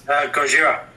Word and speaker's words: Gojira: Gojira. Is that how you say it? Gojira? – Gojira.